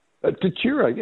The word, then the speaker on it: Tatura: Tatura? – Tatura, yes, indeed, yes.